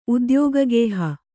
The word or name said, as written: ಉದ್ಯೋಗಗೇಹ